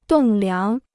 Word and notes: 栋梁: dòng liáng

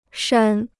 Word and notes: 删: shān

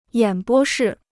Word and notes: 演播室: yǎn bō shì

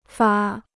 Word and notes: 发: fà

